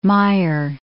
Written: mayer

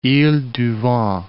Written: iles du Vent

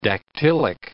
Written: dactylic

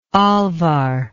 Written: alvar

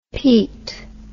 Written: pete

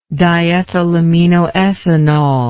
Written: diethylaminoethanol

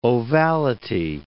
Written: ovality

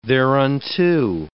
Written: thereunto

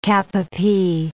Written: cap-a-pie

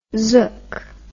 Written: zook

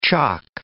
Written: chock

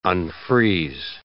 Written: unfreeze

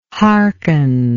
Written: harken

